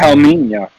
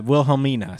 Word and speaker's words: Wilhelmina: And they come back to Krakoa and they find Wilhelmina. I'm sorry if I mispronounced. – Wilhelmina. – Yeah, Wilhelmina. Sorry.